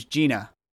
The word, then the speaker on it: Jinah: Her name's Gina, but it's spelled J-I-N-A-H.